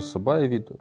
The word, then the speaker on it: сабаевид: Да, тоже сабаевид, тоже слитно произносится. И ударение на ба пойдет.